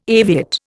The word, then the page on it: aviate: Indian